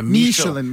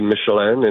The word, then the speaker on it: Michelin: I mean, in France it would be Michelin. In America we say Michelin.